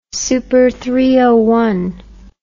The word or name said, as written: supertranscendentness